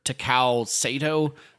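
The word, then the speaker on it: Takao Saitō: Well, it helps when Kurosawa and his cinematographer Takao Sato, I believe is how you say his name, they were like.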